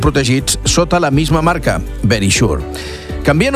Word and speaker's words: Verisure: O Verisure, lo he dicho tal cual, Verisure. Seria la pronunciació correcta. Verisure està com escrit, però és Verisure.